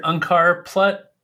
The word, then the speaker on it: Unkar Plutt: His name is Unkar Plutt. Am I saying that right? Plutt? – Yeah, I hear Unkar Plutt.